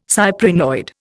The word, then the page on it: cyprinoid: Indian